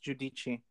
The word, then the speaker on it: Giudice: Guidis. – Examples of white. No, actually, I'm sorry. It's Giudice. Not even kidding. I literally looked that up.